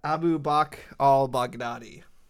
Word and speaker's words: Abu Bakr al-Baghdadi: I always just say something like from Durka Durka Muhammad Jihad, but his actual name was Abu Bakr al-Baghdadi.